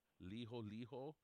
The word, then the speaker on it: Liholiho: Tell us about this particular leader of Hawaii, Liholiho, is that how you pronounce that name? – Yes, that's how you pronounce it.